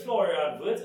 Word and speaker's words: Florian Wirtz: It's Florian Wirtz, is that I pronounce that right? – Yep.